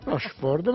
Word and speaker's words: Rochefort: Che io ho chiamato Rochefort. – Rochefort, ma va bene uguale. – Grazie. L'ho detto bene? – Secondo me sì.